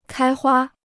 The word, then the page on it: 开花: kāi huā